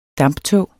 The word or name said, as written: damptog